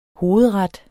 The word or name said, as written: hovedret